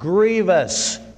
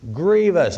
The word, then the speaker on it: grievous: And His commandments are not grievous. Grievous, that's how you pronounce that word, not grievous. Grievous.